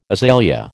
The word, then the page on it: azalea: American English